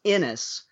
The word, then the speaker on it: Anas: It's an unusually written A-N-A-S, pronounced Ennis.